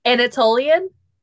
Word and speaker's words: Anatolian: Antelonian folklore. I think I'm pronouncing that right. – Anatolian? – Yes, that one.